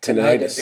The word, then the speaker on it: tinnitus: tinnitus? Did I say that right? – Tinnitus. – It can be tinnitus. Either one. It can be tinnitus or tinnitus. It can be either one.